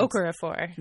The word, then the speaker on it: Okorafor: Okorafor.